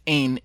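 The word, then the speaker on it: ein (ἦν): That Greek word en, or ein. I'm sorry, I'm mispronouncing it. Ein, ein pros.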